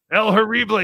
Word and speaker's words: El Horrible: El Horrible, yes they do.